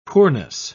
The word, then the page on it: poorness: British English